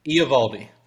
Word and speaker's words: Eovaldi: I don't know how to pronounce that. – Eovaldi.